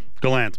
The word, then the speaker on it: Gallant: Gallant, by the way. – I know. – I mispronounced it. Gallant. – I can't do it. – Gallant. – I just can't. I don't think I'll ever get there.